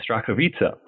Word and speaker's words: Starachowice: Starachowice, is that correct? – Very close, Starachowice. – Okay, Starachowice.